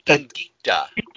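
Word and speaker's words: indicia: And usually what you do is you take a picture of the comic and then the, am I saying it right? Indica in the bottom? – Indicta. – Indicta. And, Indicta.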